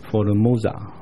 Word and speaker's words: Formosa: Ilhas Formosa。意思就是前面那个字是台岛的意思，加了 S 跟英文一样是复数，显然他不是只看到台湾岛，还看到四围的小岛。 Formosa 是他们的美丽的意思，葡萄牙文的美丽的意思，他们读作 Formosa。